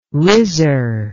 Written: rizzar

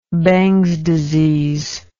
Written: Bangs disease

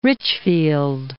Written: Richfield

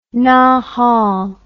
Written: nahal

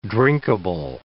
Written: drinkable

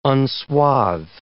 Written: unswathe

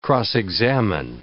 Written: cross-examine

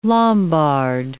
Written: Lombard

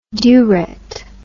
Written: dewret

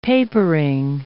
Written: papering

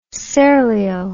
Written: serlio

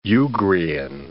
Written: Ugrian

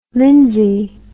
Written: lyndsay

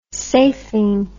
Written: safing

